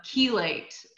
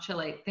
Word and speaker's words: chelate: And then chelate is how it's pronounced, not chelate.